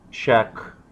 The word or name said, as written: check